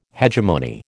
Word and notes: hegemony: American English

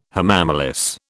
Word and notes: hamamelis: British English

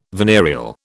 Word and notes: venereal: British English

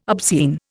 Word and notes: obscene: Indian

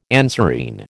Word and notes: anserine: American English

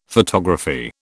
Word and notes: photography: British English

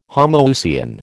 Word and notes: homoousian: American English

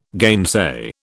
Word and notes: gainsay: British English

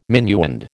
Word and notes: minuend: American English

